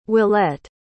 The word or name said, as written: willette